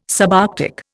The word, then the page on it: subarctic: Indian